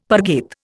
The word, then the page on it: parget: Indian